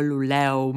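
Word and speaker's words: Luleå: że się tak specyficznie wymawia Huelefteo, Luleo, Meo, Piteo. To jest takie A z kółeczkiem na górze i ono właśnie tak się wymawia O.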